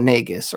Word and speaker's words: Negus: Negus rhymes with Las Vegas. N E G U S.